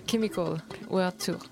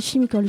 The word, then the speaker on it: Chemical World Tour: Comment on le prononce déjà Parce que tout à l'heure, j'ai pas bien prononcé. – The Chemical World Tour. – Moi, j'aurais dit chimical, je sais pas pourquoi.